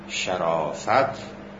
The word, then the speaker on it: شرافت: البته این را هم عرض کنم، این که ما اینطور تلفظ میکنیم، تلفظ درست تمام این واژه ها، یعنی کرامت و سخاوت و شرافت و نفاست، به فتح اول است. یعنی کرامت، سخاوت، شرافت، نفاست باید به فتح اول تلفظ بشه.